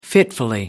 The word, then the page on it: fitfully: British English